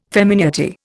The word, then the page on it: femineity: Indian